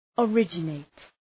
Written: originate

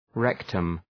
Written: rectum

